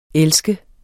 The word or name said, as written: elske